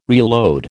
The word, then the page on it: reload: American English